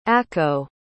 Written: acco

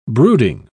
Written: brooding

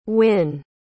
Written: Winn

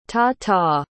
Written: ta-ta

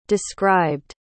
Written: described

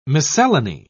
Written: miscellany

